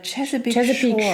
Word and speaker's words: Chesapeake: Chesapeake. Spricht man das echt Chesapeake? – Also laut dem. – Ja, so haben die das genannt. Ja. Also Chesapeake Shores.